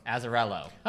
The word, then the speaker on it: Azzarello: Azzarello.